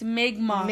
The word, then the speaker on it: Mi'kmaq: It's Mi'kmaq. – Mi'kmaq. – We were saying Mi'kmaq, or like, not, we weren't saying Mi'kmaq, but we were saying like Mi'kmaq, like.